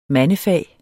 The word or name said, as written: mandefag